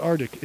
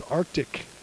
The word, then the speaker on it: Arctic: And Arctic Insulation, it's not Arctic, it's Arctic. You got to say it right.